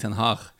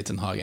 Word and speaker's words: Ten Hag: For at egentlig på sånn hollandsk så skal vel Ten Hag uttales Erik Ten Hag med en sånn rrr-lyd der han men samtidig,